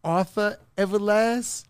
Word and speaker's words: Arthur Everlazt: Arthur Everlazt? – Arthur Everlazt.